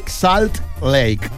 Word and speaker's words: Salt Lake: Salt Lake.